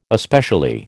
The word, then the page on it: especially: American English